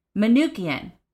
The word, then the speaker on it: Manookian: Saint and God Brian McCunican. I'm going to spell that one because I did not say it right. M-A-N-O-O-K-I-A-N. Manukian. Like, that was not that hard. I just had to spell it first.